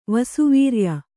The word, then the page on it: ವಸುವೀರ್ಯ: vasu vīrya